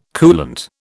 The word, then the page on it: coolant: British English